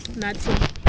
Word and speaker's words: Nathi: Nathi. – Nathi. Ben. – Nice to meet you, Ben. – Nice to meet you, Nathi. How do you spell that? – N-A-T-H-I. – Just exactly the way it sounds.